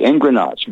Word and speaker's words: engrenage: E-N-G-R-E-N, as in Nancy, A-G-E, engrenage, gearing.